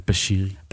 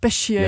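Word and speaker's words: Bachiri: Velkommen til dig, Issam Bakiri. – Mange tak. Jeg vil bare sige, det udtales Bashiri. – Bashiri. – Ja, det er fordi, det er en fransk ting.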